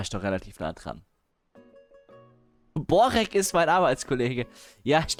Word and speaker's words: Börek: Warte mal Aussprache. Moment. Pronunciation. Nicht Börek. Spricht man das aus? In Türkisch, genau. In Dänisch? Okay. – Börek.